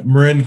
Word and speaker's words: Marin: Yeah, I mean, uh, Marin, Marin Catholic uh, is how it's pronounced.